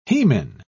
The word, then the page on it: hemin: British English